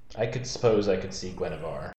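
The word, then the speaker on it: Guinevere: It's pronounced, apparently, Guinevar. – No. – I, for like, over time of saying it exactly how you did, over time, just started calling her Gunwaver.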